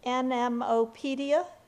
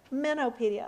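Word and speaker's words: MNopedia: NMOpedia. Is that what it goes by? – Minnopedia.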